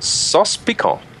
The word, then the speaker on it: sauce piquant: How do you say that again? Sauce piquant? – Sauce piquant. That's cool. It's kind of spelled like sauce picante, but it's Cajun instead of Mexican.